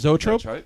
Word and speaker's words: Zoetrope: He had made his own studio for American, and maybe you can help with the pronunciation. – Zoetrope? – Is it? Yeah, okay, great.